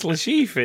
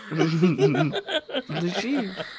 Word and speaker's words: Le Chiffre: But it's Le Chiffre, isn't it? – Oh, I don't. – Le Chiffre.